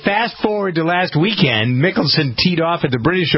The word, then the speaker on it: Mickelson: So I always pronounce it Mickelson, but you're right. Mickelson is the way he pronounces it.